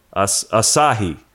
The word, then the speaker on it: Asahi: Asahi. – Asahi. – You're Asahi. – Stressing the sa.